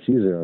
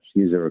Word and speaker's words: Caesar: Yeah, Caesar. I mean, it depends on the language. Yeah, Caesar or Kaisar in German or whatever.